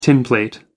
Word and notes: tinplate: American English

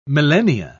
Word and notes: millennia: British English

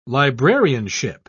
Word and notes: librarianship: British English

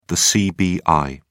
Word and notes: cbi: British English

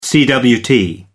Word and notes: cwt: American English

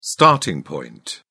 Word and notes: starting point: British English

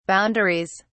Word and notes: boundaries: British English

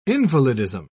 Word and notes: invalidism: British English

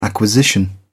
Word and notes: acquisition: British English

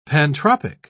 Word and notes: pantropic: British English

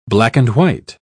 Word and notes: black-and-white: British English

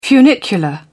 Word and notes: funicular: British English

